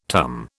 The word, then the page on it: tum: British English